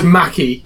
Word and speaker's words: Mackie: Say it as you see it. It's Mackie.